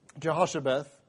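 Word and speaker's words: Jehoshabeath: you pronounce it Jehoshua. Is that correct? Jehoshabeth.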